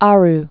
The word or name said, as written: Aroe Islands